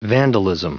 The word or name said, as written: vandalism